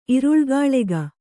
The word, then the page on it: ಇರುಳ್ಗಾಳೆಗ: Kannada